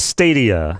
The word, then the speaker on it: Stadia: It is Stadia.